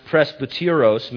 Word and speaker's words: presbyteros: And there's a Greek word pronounced presbyteros, meaning older or senior.